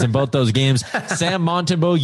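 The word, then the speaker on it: Montembeault: It's Montembeau, by the way. Yeah, that's how you say it.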